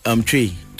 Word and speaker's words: Twi: Tree. – Tree. – Yeah. – Tree. – Tree. Is that T-W-I, but it's yes, but you say it like tree.